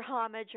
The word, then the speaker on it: homage: Homage is a pronunciation that I hear a lot more often these days. – And so that's why it has now been adopted as an okay pronunciation by Merriam-Webster's.